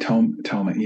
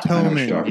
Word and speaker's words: Thomann: Do you have any experience with like, Thomann or how do you say it? It's TH. – Like Thomann, yeah. – Thomann, yeah, there it is.